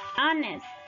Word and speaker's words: honest: Silent H, la H silenciosa. Ghost, ghost, fantasma. Honest, honesto.